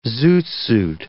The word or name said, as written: zoot suit